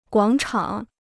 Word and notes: 广场: guǎng chǎng

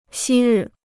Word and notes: 昔日: xī rì

